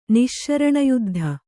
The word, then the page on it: ನಿಶ್ಶರಣಯುದ್ಧ: Kannada